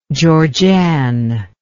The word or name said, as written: Georgann